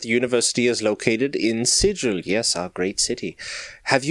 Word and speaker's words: Sigil: It is absolutely pronounced Sigil. It has been confirmed by Jeremy Crawford that it is supposed to be pronounced Sigil.